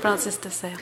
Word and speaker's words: Francis de Sales: Found this piece from one of the saints from one of the traditions, all our guides, all wise beings, St. Francis de Sales? How do you say it? St. Francis from Sales. St. Francis de Sales.